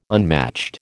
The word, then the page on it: unmatched: American English